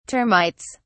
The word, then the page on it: termites: British English